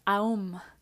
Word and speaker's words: om: Bueno, la palabra de hoy es el om, que se pronuncia aum.